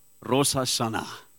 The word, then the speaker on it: Rosh Hashanah: Is that now correct, Herman? Herman said to me this morning's pronunciation was wrong. Sê vir ons gou daai, Herman, skryf ons uit. Rosh Hashanah. Let's say that.